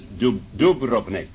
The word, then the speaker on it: Dubrovnik: They began on June 24th, 1981, in a small mountain town known as Medjugorje, near the area and resort area known as Dubrovnik, is really the way you pronounce that.